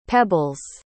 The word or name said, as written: pebbles